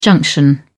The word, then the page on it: junction: British English